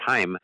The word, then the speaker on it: Chaim: We mispronounced his name the other day because it is Chaim, but it's pronounced Chaim, as I thought it might be.